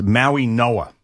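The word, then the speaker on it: Mauigoa: Yeah, we're going to have to get this right because I've screwed up many times as well, but it's Maui Noah. That's the way we got to pronounce it.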